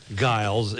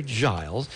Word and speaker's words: Giles: I correctly said it was Barry Giles, and then I said that's a hard G. No, a hard G is Giles. It's a soft G. So Barry Giles.